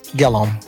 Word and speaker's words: Gillum: I really thought that candidate, Gilliam, is I said it right, Gillum? – Gillum.